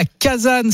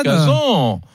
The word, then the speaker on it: Kazan: Kazan. – Kazan. – Alors je disais Kazan et puis je suis passé à Kazan euh, récemment parce qu'on m'a dit que c'était la meilleure prononciation en français.